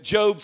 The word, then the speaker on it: Job: Job, you know Job, some of you don't ever read Job because it's spelled Job. Job, the book of Job, not Job, the book of Job.